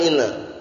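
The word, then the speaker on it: inna (إنّ): Jadi kalau inna itu letaknya di permulaan antum baca anna keliru. Anna Zaidan qa'imun khata. Nah, terus bagaimana inna? Karena di permulaan. Paham ya insyaallah ya? Fil ibtida. Pertama di permulaan. Kalau di permulaan mesti bacanya inna.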